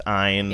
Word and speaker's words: Ayn: And by the way, I know it's pronounced Ayn.